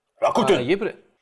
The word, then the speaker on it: Rakuten: Боюсь неправильно назвать ударение, Rakuten или Rakuten пишется через K. Rakuten, по-моему. – Rakuten!